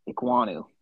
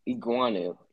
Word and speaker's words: Ekwonu: I think it's pronounced Akeem. Ekwonu. – Ekwonu. Yeah, Ekwonu. I